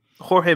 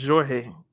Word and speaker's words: Jorge: It wasn't Cristiano, but and then this summer, Jorge Mendes, or is it Jorge? I'm not sure how you pronounce the Portuguese J, but Mendes, he was twerking all summer. – Oh no, it's Jorge. You pronounce the J in Portuguese.